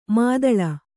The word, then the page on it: ಮಿದುಲು: midulu